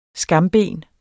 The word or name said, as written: skamben